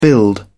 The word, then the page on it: build: British English